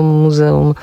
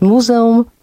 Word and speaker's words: muzeum: Dlaczego Muzeum Sztuki Nowoczesnej, a nie współczesnej? Na wszelki wypadek, powiem, sprawdziłam w słowniku, można dwojako akcentować muzeum lub muzeum.